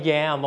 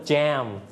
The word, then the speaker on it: jam: yam บางคนออกเสียงว่า jam both are correct if you call it in Thai you may call it like yam but in English you can call it like jam